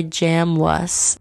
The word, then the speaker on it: Jamwus: that is going to Toronto by Jam Wuss. – Feeling like a different planet from you. Oh, definitely go check it out. Jamwus, it's spelled J-A-M-W-S, but it's pronounced Jamwus, trust me.